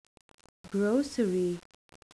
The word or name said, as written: grocery